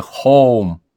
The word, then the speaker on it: Хьюм: Написание имени Дэниела Дангласа Хьюма изменено, чтобы не вводить читателя в заблуждение, хотя на самом деле произносится «хоум» — «дом».